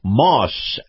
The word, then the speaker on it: Maus: Now, my conversation with the watchdog on Wall Street, Chris Markowski, is brought to you by Moss Acura. Moss is spelled M-A-U-S.